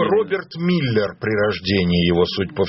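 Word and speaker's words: Müller: Вполне возможно, что на самом деле звали его, я думаю, не Миллер, а Мюллер. Миллер – это он в Соединенных Штатах, вот в его свидетельстве о смерти. Значит, вполне возможно. – Как прокурор, на самом деле, произносится как Мюллер.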